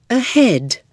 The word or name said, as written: ahead